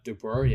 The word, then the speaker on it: DeBoer: DeBoer, yeah, that's how you say his name, the coach of the Vegas coach, Golden Knights.